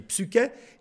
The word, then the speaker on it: psyché: ça s'écrit psyché, P-S-Y-C-H-E, et donc ça se dit psyché, et cela relève précisément de l'immortalité et de l'immatérialité.